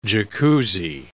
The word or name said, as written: Jacuzzi